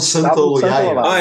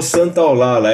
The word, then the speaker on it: Santaolalla: É o Gustavo Santaolalla. Ah, é, Gustavo Santaolalla.